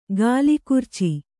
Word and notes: ಕಲಗರ್ಚು: Kannada

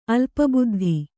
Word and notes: ಅಲ್ಪಬುದ್ಧಿ: alpabuddhi

